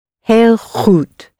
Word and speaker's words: heel goed: H-E-E-L. How would you say very good? – Heel goed. – Heel goed. So hail is like hail the chief. Hail to the chief, or it's hailing outside. Hail for very.